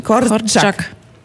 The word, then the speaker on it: Korczak: Und Korczak, das ist schon ein, da ist noch irgendwie wie so ein T dazwischen. Korczak.